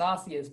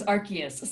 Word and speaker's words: Arceus: Anyway, I would like to say that it was Arceus, but the official pronunciation is Arceus.